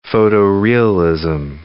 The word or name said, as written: photo-realism